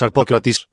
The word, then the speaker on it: Arpocratis: El nombre de Arpócrates es Arpócrates, pero como mantram es Arpocratis, Arpocratis, y entonces uno se concentra.